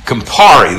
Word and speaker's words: Campari: I was going to ask you about one of my mom's favorite tomatoes, and it's called the if I'm saying it right, Campari? – Campari, that's right.